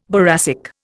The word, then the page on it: boracic: Indian